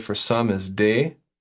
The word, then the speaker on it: des: Des. Des. – Des. Okay, real good. That's spelled D-E-S.